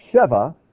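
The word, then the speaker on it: sheva (שבע): When you pronounce it sheva, it means seven.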